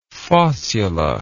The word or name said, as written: fossula